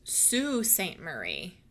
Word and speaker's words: Sault Ste. Marie: It is pronounced Sault Ste. Marie.